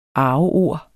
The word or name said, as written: arveord